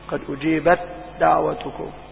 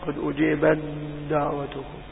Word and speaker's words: أجيبت دعوتكم: أقول إذا أردت أن أنطقها لك مظهرة وهي لا تقرأ هكذا، أقول قد أجيبت دعوتكم، وهذا خطأ، إنما هي تقرأ قد أجيبت دعوتكم.